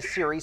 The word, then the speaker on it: Seary: Even though Alexa Siri spells her last name S-E-A-R-Y, it's pronounced the same as the iPhone version.